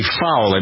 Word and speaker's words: Fowle: So the message first from Jeffrey Fowle, I believe that's how you pronounce his name, F-O-W-L-E, one of the Americans detained in North Korea, sending this to his family in Ohio.